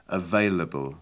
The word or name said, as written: available